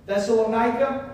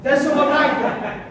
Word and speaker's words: Thessalonica: Paul is writing to the church in Thessalonica, or however you pronounce that city. What'd you say? – Thessalonica? – Yes. That'll work. Thessalonica.